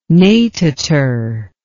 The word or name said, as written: natator